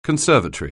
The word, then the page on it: conservatory: anglais